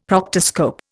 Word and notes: proctoscope: Indian

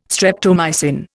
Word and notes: streptomycin: Indian

